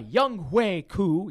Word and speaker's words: Younghoe Koo: Younghoe Koo.